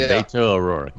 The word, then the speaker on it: Beto O'Rourke: Well, I don't know how you say it. Is it Beto O'Rourke? Beto O'Rourke? – Yeah, Beto O'Rourke.